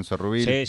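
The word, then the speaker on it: Sourrouille: Bueno, después, no sé si se acuerdan, Juan Zurruil. – Sí, lo estoy pronunciando bien, Juan Zurruil. – Sí, perfecto.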